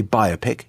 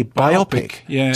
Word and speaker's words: biopic: Bio to almost rhyme with silo. Anyway, everyone started laughing. In fact, the table collapsed into hysteria. They all pointed at me. And they said, you don't say biopic. You have to say biopic to rhyme with myopic.